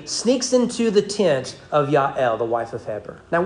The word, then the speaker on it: Jael: Uh, and that's assuming you don't pronounce the J. If you do pronounce the J, because it's Hebrew, they don't have J's, it would be Jael. Um, but I believe her name is pronounced Yael.